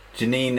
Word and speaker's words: Jeanine: I think she's also kind of Janine in a French way, J-E-A-N-I-N-E. – Yes. – Janine.